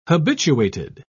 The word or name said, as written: habituated